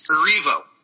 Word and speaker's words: Erivo: Erivo.